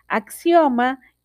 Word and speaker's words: axioma: Axioma es una proposición tan evidente que no requiere previa demostración, es decir, o es que es una evidencia lógica Axioma es una palabra llana, no se acentúa en la penúltima sílaba debido a que termina en vocal.